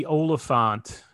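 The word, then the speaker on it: Olyphant: Yeah, Timothy Oliphant, if that's how you say his name.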